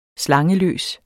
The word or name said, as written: slangeløs